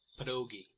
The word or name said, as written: pierogi